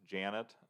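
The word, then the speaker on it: Ja'net: and then I assume it's pronounced Janet, but it's J-A apostrophe N-E-T.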